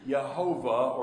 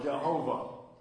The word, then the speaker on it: Jehovah: They took the word Adonai, all the vowels out of Adonai, they put into the tetragrammaton, this four-letter word, and they came out with the way to pronounce it, which is Yehovah or Jehovah.